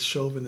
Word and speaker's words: Chauvin: A lot of y'all are mispronouncing his name. It's Chauvin.